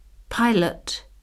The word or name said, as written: pilot